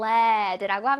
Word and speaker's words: lead: lead라고 원래 발음이 되는데 여기에서는 lead 연필심 할때 lead는요. 아, lead는요. 납이란 뜻이거든. 납. 그래서 납은 영어로 lead로 읽어요. 그래서 lead라고 읽기 LEAD가 lead라고 읽힐 때도 있지만 여기 연필심, 샤프심을 뜻할 때는 lead라고 읽힙니다. 이거 lead라고 하면 안 돼요.